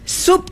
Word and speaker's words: sub: Sub tajuk. Sub ya, bukan sub. S-U-B. Sebab kita ejaan bahasa Melayu mengikut huruf, bunyi huruf. A bunyi A. Kita tak boleh A bunyi A. A-R-I-E-U-U.